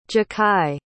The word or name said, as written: jachai